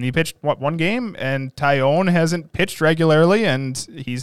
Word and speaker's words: Taillon: Taillon.